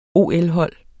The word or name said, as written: OL-hold